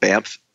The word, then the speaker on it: Banff: Well, there's a small like kind of a mountain resort town called Banff. B-A-N-F-F. It sounds like I'm saying an M.